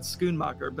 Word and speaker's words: Schoenmaker: She's from Johannesburg, so I wanted to hear someone who's actually there pronounce it, because I would not have said Schoenmaker, but that's apparently how it's pronounced.